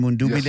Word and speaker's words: Mundubile: Am I pronouncing his name properly? Brian Mundubile, yes.